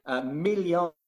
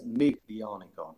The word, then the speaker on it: Miglionico: Milionico. We would read it as Miglionico. We would spell it Miglionico.